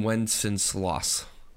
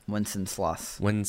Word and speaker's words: Wenceslas: Good King Wenceslas. – Wenceslas.